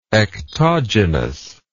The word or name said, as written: ectogenically